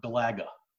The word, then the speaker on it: Galaga: Galaga or Galaga? – Galaga. I say Galaga. It's Galaga. – Galaga. Okay. – Yeah. You said Galaga? – I have weird friends that can't pronounce things. – Yeah, no, it's definitely Galaga.